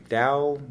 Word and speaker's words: Dow: In reality, his ancestors in the English Welsh border spelled their name Du, like D-D-U, which is Welsh for black, which is actually cognate with my last name, Dow, it used to be pronounced Dub.